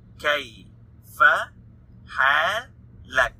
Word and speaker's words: kayfa halak: So kayfa, halak, four syllables. Two words, kayfa halak, kayfa halak.